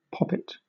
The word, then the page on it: poppet: British English